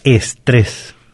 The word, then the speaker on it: estrés: Pero esta palabra la tenemos castellanizada en nuestro diccionario y es estrés. – Estrés. – Empezando con E y terminando con una sola S. Y con acento. Con acento en la última E.